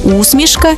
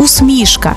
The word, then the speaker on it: усмішка: До речі, тут подвійний наголос – усмішка і усмішка, як у Василя Симоненка.